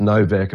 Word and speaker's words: Nowak: So what I did is just looked at the name and it's pronounced Novak in Polish, but it's Nowak if you look at it.